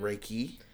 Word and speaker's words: Reiki: Is it Reiki? – It's R-E-I-K-I. – Gotcha. – It's pronounced like Reiki.